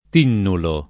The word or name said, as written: tinnulo